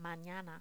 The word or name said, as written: manana